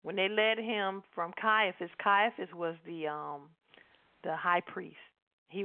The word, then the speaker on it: Caiaphas: Then let they, Jesus, from, I pronounce that, Caiaphas. – Caiaphas, unto the hall of judgment, and it was early.